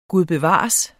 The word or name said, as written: gud